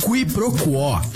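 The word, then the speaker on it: quiproquó: Qui pro quo. Q-U-I-P-R-O-Q-U-O com acento agudo. Qui pro quo, também formalmente pronunciada como qui pro quo, é a ação de confundir uma coisa com outra, ou o desentendimento decorrente dessa confusão.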